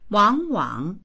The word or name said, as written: wang3wang3